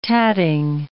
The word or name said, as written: tatting